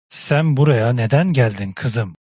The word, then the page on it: geldin: Turkish